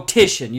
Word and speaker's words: Titian: It's it's Titian, is it? Or oh, Titian. You're right.